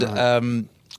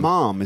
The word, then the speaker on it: ma'am: And ma'am, I think. – Ma'am. Fine. It was ma'am. You had to say ma'am. – Mom, isn't it? – No, it's ma'am to rhyme with jam.